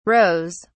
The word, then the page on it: rows: British English